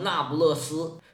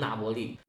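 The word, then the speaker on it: Napoli: 比较著名的就是那波利嘛，有的把它读成那不勒斯。其实那个是英文的翻译，意大利语的发音就应该翻成那波利。那波利里边这个“ 那” 呀，其实就是新的意思，英文这个 “new” 的意思。那波利意译过来就是新城的意思。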